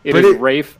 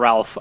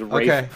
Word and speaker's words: Ralph: Rafe Fiennes? There's an L. It's not French. Nope. It is British. – It is Rafe, not Ralph. I promise you, it is Rafe Fiennes.